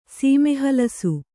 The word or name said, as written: ಸೀಮೆಹಲಸು